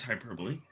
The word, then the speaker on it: hyperbole: Is it hyperbole or hyperbole? – it's hyperbole, but I was just going to let him move on.